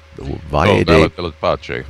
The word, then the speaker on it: Valle della Pace: Valle della Pace. – Oh, it's Italian. I was trying to make French out of it.